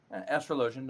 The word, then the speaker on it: Astrologian: I know I just mispronounced that. – Astrologian, no, that's right.